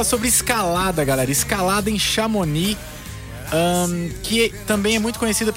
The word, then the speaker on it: Chamonix: E o Cume FM de hoje te leva diretamente para Chamonix, na França. Falei certo, Paulo? – Sim. Mas bem que eu ouvi gente falando de forma diferente, Chamonix. – Porque tem um X ali, um X mudo, né? – Pois é.